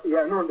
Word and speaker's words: Yannuwn: They say Yannuwn, it's pronounced Yannuwn, depending on the dialect of what galaxy you're in, the pronunciation changes.